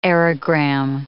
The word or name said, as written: aerogram